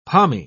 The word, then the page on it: pommy: British English